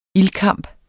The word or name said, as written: ildkamp